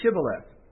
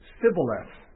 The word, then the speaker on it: Shibboleth: And it's a word that didn't really have any significance, except that they knew that Ephraimites could not pronounce it correctly. And so they said, "Then say Shibboleth." And he would say Sibboleth, for he could not pronounce it right.